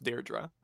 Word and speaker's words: Deirdre: I saw Liam comment that A, he wasn't crying, so that's good, but also that it is pronounced Deirdre. So Deirdre. We're sticking with Deirdre.